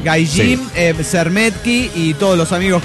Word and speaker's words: Gaijin: La H suena como J, Yokohama, ese Hama se escribe con H pero es con J, se dice con J, Hokkaido, y la J suena como Y, es decir, Gaijin, Romaji o Meiji.